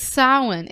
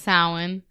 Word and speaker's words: Samhain: It's Sawin. It's pronounced Sawin. We've gone over this already.